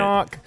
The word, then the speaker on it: Shinnok: Mortal Kombat Onslaught is the first mobile exclusive action-packed story centered on Shinnok. I know BK's going to yell at me for that. – Shinnok. There's an H in it. Shinnok. There it is.